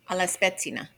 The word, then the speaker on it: spezzina: I was saying it wrong. Alla spezzina.